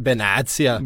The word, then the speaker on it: Benatia: Det kom ju också rykten om Benatia, eller som han egentligen... Benatia. Som det ska uttalas tydligen.